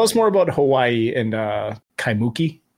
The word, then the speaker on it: Kaimuki: Yeah, Kaimuki is how you pronounce it. – Okay, so the emphasis is over the key at the end, so it's Kaimuki? – Yes.